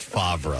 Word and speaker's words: Favre: By the way, it's pronounced Favre.